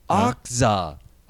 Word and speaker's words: Okja: Oh, here's how you pronounce it. Okja. – Okja. – Not Okja, Okja. – Okja. Okja. – Okja. – Okay. – Oh, it's O-K-Z-A, Okja.